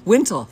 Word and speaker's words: wimtle: Wintle. – Spell it. – W-I-M-T-L-E.